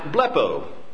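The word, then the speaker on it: blepo (βλέπω): Now this is the Greek verb that's pronounced blepo in the Greek, and it means to look at or to take a quick survey, a quick glance.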